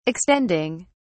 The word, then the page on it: extending: British English